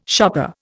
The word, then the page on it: shopper: Indian